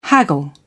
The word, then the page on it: haggle: British English